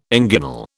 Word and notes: inguinal: British English

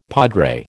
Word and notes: padre: American English

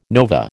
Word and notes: nova: American English

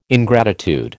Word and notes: ingratitude: American English

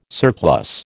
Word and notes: surplus: American English

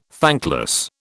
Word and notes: thankless: British English

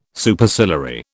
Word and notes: Superciliary: British English

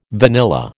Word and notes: vanilla: American English

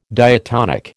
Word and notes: diatonic: American English

